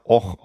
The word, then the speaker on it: Avoch: Also wie gesagt, der, der Ort, wo wir hinfahren, der heißt Och. Och. Ja, woher soll man wissen, dass A-V-O-C-H Och ausgesprochen wird? – Ja.